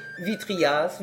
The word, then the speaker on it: vitriaise: Dans l'usage, c'est vitrier, vitriaise, qui doit être prononcé vitrias, vitrias.